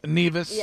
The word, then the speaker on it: Nevis: Am I saying that right? – Nevis. Yeah, you went to Nevis.